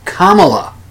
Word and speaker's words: Kamala: Anyway, I called her Kamala Harris. Kamala Harris. When it's Kamala Harris, the emphasis is on the first syllable, not the second.